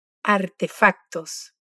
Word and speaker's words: artefactos: Ojo, artefacto tiene una C en el medio de la palabra y la pronunciamos, repítelo, artefactos.